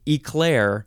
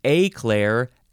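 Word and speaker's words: éclair: The differences are you can either emphasize the first syllable or the second syllable, and then you can pronounce the first vowel different ways. Eclair, aclair, eclair, aclair, or eclair, aclair, eclair. Yeah, you get it. It is spelled E-C-L-A-I-R, and there is an accent on the E, which goes boop.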